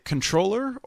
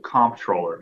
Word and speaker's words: comptroller: I actually, though, came up with the most important question, and given your background, Greg, do you pronounce it controller or comptroller? – I pronounce it comptroller because that's the way it's spelled, but it's certainly an internal debate that's continuously happening within the agency and outside the agency as well. But I go by comptroller.